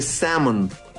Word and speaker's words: salmon: Pero se pronuncia sin la L y con una A más extendida. Se dice salmon.